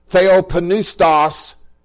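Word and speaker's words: theopneustos (θεόπνευστος): Now the word that was translated into English here as inspired comes from a Greek word, theopneustos. Yes, you pronounce the letter P in that word, which has to do with breath, especially exhaled breath.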